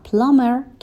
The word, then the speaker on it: plumber: فعل force به معنای مجبور کردن هست و plumber که بی توش سایلنت هست و تلفظ نمیشه به معنای لوله کش هست.